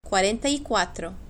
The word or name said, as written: cuarenta y cuatro